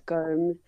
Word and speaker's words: Gomes: It's Gomes. Just Gomes. – Oh, so it's a silent S.